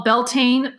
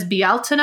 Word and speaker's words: Bealtaine: And so we're right in the tides of what Americans call Beltane. The correct pronunciation is Bealtaine.